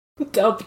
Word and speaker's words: Delp: Delp, Delp, it rhymes with kelp.